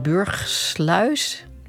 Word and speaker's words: Burgsluis: Vandaag wordt in Burgsluis, ja, zeg ik het goed? Ja, de eerste oogst binnengehaald.